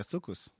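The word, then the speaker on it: soukous: It's, you know, this is the first time I hear a non-African pronounce that word. – Is that's how you say it? – Yeah, sukus.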